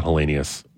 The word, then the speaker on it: Helenius: No, not Helenius. – Yeah. Helenius is how you pronounce it, but yeah.